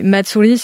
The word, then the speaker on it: matsuri: On va avoir, par exemple, des matsuri, ce qu'ils appellent, ce sont des festivals.